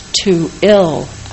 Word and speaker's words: Tooele: Tooele is pronounced Tooele.